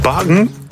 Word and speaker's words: Bakken: Bagen? Zo wordt het dus blijkbaar uitgesproken in het Deens.